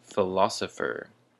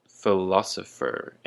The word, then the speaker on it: philosopher: So our first word for today is philosopher. Philosopher, and the emphasis is on the la.